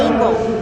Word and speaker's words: Ingle: I'm Martha, like the New Testament, and Engel, like Little House on the Prairie. – With the I? – Yes, sir. – Okay, good. I-N-G-L-E.